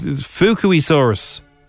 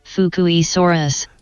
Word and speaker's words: Fukuisaurus: This is how you do pronounce it. Hang on now. Here we go. You ready? – Fukuisaurus. – But there's no E. – I know. It's Fukuisaurus. – Fukuisaurus.